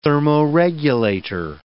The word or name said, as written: thermoregulator